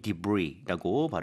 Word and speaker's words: debris: 일단은 여러분들 debris라고 하는 건 S가 있어도 여전히 debris라고 발음을 해야 되고요.